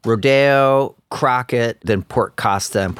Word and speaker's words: Rodeo: And we're pronouncing it Rodeo, right? It's not Rodeo.